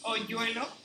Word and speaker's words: hoyuelo: H-O-Y-U-E-L-O. Oyuelo. – Yeah. – Did I say it correctly? – You pronounce it like as if it was two L's. Oyuelo.